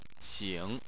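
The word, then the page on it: xing: tone 3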